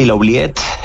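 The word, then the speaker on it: Oubliette: Hay un lugar que se llamaba La Ubiet. – Sí. – No sé cómo se pronuncia, si se pronuncia así. – Sí, La Ubiet. Es un poco el término francés, como de oublier, como de olvidar, ¿no?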